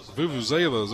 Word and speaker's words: vuvuzelas: Vuvuzelas, there we go.